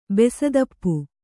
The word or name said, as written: ಬೆಸದಪ್ಪು